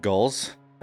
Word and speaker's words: ghul: I think they're actually called gulls. and I've been mispronouncing them. That's actually spelled G-H-U-L.